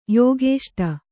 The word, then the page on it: ಯೋಗೇಷ್ಟ: Kannada